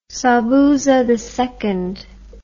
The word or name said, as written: Sobhuza II